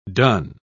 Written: done

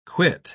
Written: quit

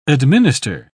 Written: administer

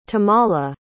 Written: tamala

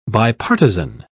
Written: bipartisan

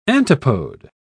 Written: antipode

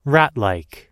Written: ratlike

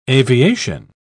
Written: aviation

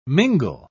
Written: mingle

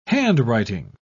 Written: handwriting

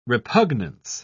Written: repugnance

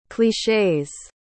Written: cliches

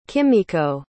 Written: kimiko

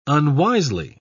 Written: unwisely

